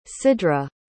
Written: sidrah

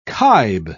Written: kibe

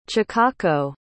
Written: chikako

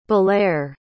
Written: balere

